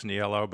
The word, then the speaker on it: Niello: And I'm saying it wrong, Niello, right? It's Niello. I'm calling it. I'm like pronouncing it. – Niello is the way they say it in the mother country. – Uh-huh. – If you're in Italy, it's Niello, but it kind of got anglicized, I guess, when my grandfather emigrated.